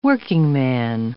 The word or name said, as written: workingman